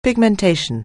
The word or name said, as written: pigmentation